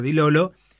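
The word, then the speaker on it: Di Lollo: Con Dilolo, como vi que dijo que hay que pronunciar Dilolo.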